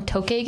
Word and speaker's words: Tokay: Oh, like, wait, that's spelled T-O-K-A-Y? – T-O-K-E, I'm dyslexic, so I Tokay, I think I always call them Tokay geckos.